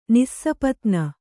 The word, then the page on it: ನಿಸ್ಸಪತ್ನ: nissapatna